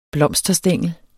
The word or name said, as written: blomsterstængel